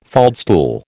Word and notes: faldstool: American English

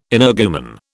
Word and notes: energumen: British English

